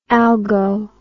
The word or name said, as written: algo